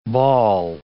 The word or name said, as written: bawl